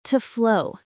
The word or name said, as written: to flow